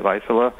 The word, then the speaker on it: Vaisala: What kind of work do you do at Vaisala, which is spelled V-A-I-S-A-L-A? – Yes, it's Vaisala. It's a Finnish company, headquartered out of Finland.